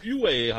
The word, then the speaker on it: Huawei: Ich glaube, Huawei heißt es.